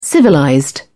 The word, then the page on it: civilized: British English